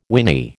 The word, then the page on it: whinny: American English